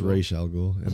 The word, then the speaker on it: Ra's al Ghul: It's supposed to be it's Ra's al Ghul.